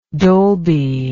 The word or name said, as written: Dolbyized